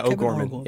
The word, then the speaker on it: O'Gorman: Yeah, O'Gorman.